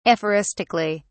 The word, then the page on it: aphoristically: British English